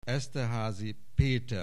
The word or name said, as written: Esterházy, Péter